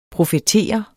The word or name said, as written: profetere